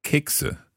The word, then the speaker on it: Kekse: Kekse. How do you spell that? – K-E-K-S-E. – K-E-K-S-E.